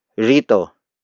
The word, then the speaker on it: rito: The next word is rito, rito, rito. Rito is spelled as R-I-T-O.